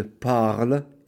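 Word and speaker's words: parlent: The ENT is going to be silent. Il parle. They speak. ENT. Don't pronounce it.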